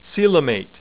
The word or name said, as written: coelomate